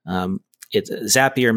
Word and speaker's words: Zapier: Zapier makes you happier.